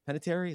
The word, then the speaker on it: Panettiere: Panettiere, is that how you say it? – Yeah.